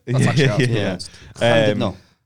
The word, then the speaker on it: Llandudno: Llandudno.